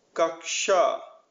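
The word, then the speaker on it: Kaksha (कक्षा): Ksh, like kush in cushion with less emphasis on the U. Kaksha, class.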